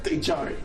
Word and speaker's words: Chari: Yeah, I know. Chari. It's Spanish. – It's Chari? – People of America call it Ichari.